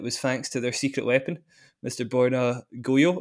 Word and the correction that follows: Gojo: And it is Gojo. – It is Goyo.